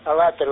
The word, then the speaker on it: Алатырь: Принял, спасибо, Алатырь, да, или Алатырь. Алатырь, ударение на второй слог.